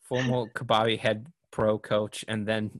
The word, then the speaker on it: kabaddi: I need to actually physically see it to remember. Kabaddi. Okay, I got it. Kabaddi.